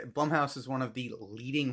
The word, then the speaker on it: Blumhouse: It was directed by Lucy Jordan, and it was at least in part made by Blumhouse, Blumhouse, that's how you say it.